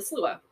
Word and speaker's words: sluagh: So, number 7, they call the Slua, which you would think is spelled like S-L-U... I don't know, but it's spelled like S-L-U-A-G-H. Slua!